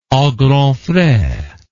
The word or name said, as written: a grands frais